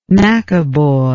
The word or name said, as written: maccaboy